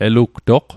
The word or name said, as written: elugtoq